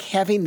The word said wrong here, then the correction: halving: the dose, how do you pronounce that? – Halving. – But why it's not halving? – Halving the dose. – Get that L in there.